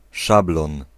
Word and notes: sjabloon: /ʃɑ'blo:n/

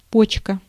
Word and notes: почка: /ˈpot͡ɕkə/; potška

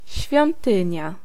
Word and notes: schrijn: /sxrɛin/

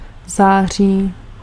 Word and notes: september: /ˈsɛpˈtɛmbɛr/

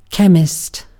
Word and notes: Chemiker: /ˈçeːmikɐ/

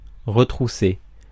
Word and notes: retrousser: France; [ʁǝ.tʁu.se]

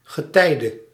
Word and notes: tide: American English

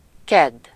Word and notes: tiistai: [ˈt̪iːstɑi]; /ˈtiːs.tɑi/

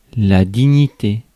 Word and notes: dignité: France; [di.ɲi.te]

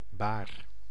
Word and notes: wave: /ˈweɪv/

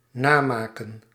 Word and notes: copier: France; [kɔ.pje]

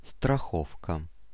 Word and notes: safety: American English; /ˈseɪftɪ/

